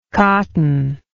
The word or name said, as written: cottonless